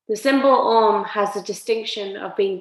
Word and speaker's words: Om: Om comprises of three sounds, A-U-M. Yeah, you see it written as A-U-M. So when Om is chanted, it comes out as A-U-M, A-U-M.